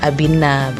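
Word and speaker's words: Abhijñā: Kalau Latinnya sih tulisannya A-B-H-I-J-N-A. Bacanya sih kalau gak salah Abhinna begitu. Agak-agak ribet sih.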